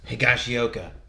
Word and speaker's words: Higashioka: My mind just went Higashioka. – Higashioka.